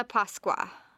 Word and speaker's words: Pasqua: Buona Pasqua. – I said it wrong. Buona. – B-U-O-N-A, so buona. – Buona. – And then P-A-S-Q-U-A, Pasqua. – Pasqua. I said it's like Pasqua with a W. Pasqua.